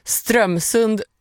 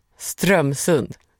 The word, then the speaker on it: Strömsund: Strömsund uttalas med betoning på första stavelsen. Strömsund. – Strömsund.